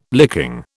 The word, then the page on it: licking: British English